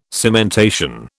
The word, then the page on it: cementation: British English